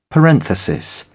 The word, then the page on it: parenthesis: British English